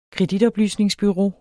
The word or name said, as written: kreditoplysningsbureau